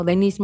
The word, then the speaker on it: Denise: Pueden buscarme en, en mi perfil de LinkedIn o en mi página de Facebook como Denise Matienzo Rubio.